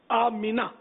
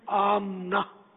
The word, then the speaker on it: آمنہ: آمینہ، میم کے نیچے زیر ہے، آمینہ، ہمارے یہاں میم کو ساکن پڑھتے ہیں عام طور پہ، آمنہ بولتے ہیں، تو صحیح تلفظ ہے آمینہ، یہ عربی ہے آمینہ۔